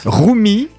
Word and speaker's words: Rumi: Rumi. Alors j'espère que je prononce. C'est comme ça qu'on dit. – Ouais. Oui.